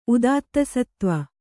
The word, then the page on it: ಉದಾತ್ತಸತ್ತ್ವ: udāttasattva